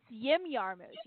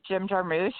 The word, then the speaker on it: Jarmusch: It's Yim Yarmouche. You actually pronounce it Yim Yarmouche. Oh, Yim.